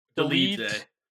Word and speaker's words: Dolidze: Roman Dolidze, how does he pronounce it? – Dolidze.